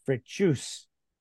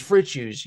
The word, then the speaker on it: Fritzius: Okay, so the audience just has gotten to understand the essence of Mike Fritzius. How'd I do? – You did Juice? Good. You got the accent. No, it's Fritzius. You got the accent on Fritzius. – The wrong syllable.